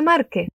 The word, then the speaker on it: Márquez: La R suena un poco vibrante. Mar, Márquez. Ahora con esa entonación de pregunta. Gabriel García Márquez. ¡Qué estupendo! – Gabriel García Márquez, o Gabo, ya se ha muerto, era un escritor colombiano genial y ha sido galardonado ya con un Nobel de Literatura.